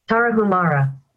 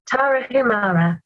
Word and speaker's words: Tarahumara: And I don't know if anyone can hear this, but this is the American pronunciation. – Tarahumara. – Just like Tarahumara. But for some reason, when I Google searched it, it came up with the British pronunciation. – Tarahumara.